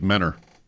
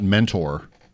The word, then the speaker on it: Mentor: It sounded like Menor to me, which I learned as a young boy is not mentor. It's Menor. – See, I didn't know that, but it's spelled like mentor. – It's spelled exactly like mentor. – It's Menor, Ohio. – It's Menor. – The T is silent.